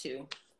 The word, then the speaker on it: TOU: So it's another name you can add to the pot, but it's pronounced Two. Like the number two.